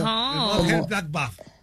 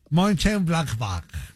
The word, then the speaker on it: Mönchengladbach: Mönchengladbach. – Del Mönchengladbach. Así, exacto. Así como lo pronunciaría, así es. Así va el alemán. – Mönchengladbach.